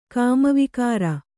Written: ಕಾಮವಿಕಾರ

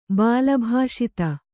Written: ಬಾಲಭಾಷಿತ

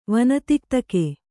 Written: ವನತಿಕ್ತಕೆ

